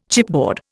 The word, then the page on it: chipboard: Indian